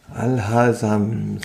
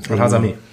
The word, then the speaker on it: Al-Hasami: Alasemi ähm, oder wie Yibi, ich wollte einmal nur den Namen aussprechen. – Al-Hasami. Al-Hasami.